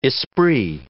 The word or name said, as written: esprit